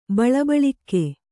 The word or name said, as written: ಬಳಬಳಿಕ್ಕೆ